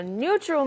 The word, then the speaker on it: neutral: Neutral, new처럼 발음하는 사람도 있고, new처럼 발음하는 사람도 있어요.